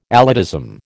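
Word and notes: élitism: American English

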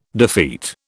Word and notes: defeat: British English

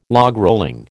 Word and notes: logrolling: American English

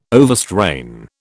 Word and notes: overstrain: British English

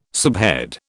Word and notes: subhead: British English

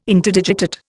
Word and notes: Interdigitate: Indian

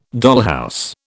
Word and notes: dollhouse: British English

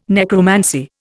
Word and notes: necromancy: Indian

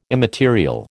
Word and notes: immaterial: American English

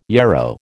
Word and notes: yarrow: American English

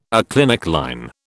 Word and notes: aclinic line: British English

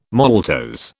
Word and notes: maltose: British English

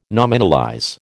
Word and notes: nominalize: American English

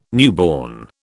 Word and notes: newborn: British English

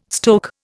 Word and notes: stoke: Indian

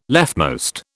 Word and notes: leftmost: British English

